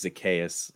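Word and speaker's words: Zaccheaus: I mean, granted, I know this happened because of injuries, but like, is he any worse than ah, I'm gonna butcher this dude's Olamide Zacharias? Is that how you say his name? Is he any worse than that guy? Probably. – Olamide, Zac- I think it's Zacchaeus. Zacchaeus. But...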